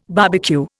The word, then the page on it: barbecue: Indian